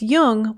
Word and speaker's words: Jung: Just be sure to pronounce Jung with a hard J.